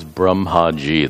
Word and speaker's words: Brahmaji: Notice I'm using the short A. Long A is Brahmaji, the creator. The short A distinguishes Brahman, the Sarva Adhishtana.